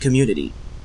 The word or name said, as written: community